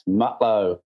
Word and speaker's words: Mutlow: Yes, Mutlow.